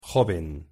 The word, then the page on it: joven: Spanish